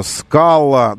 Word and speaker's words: Scala: Среди них Seat, Tarraco, Skoda Scala. Да, кстати, нужно произносить именно Scala. Не Scala, хочется сказать на манер на манер Scala. Но вспоминайте всегда La Scala. La Scala.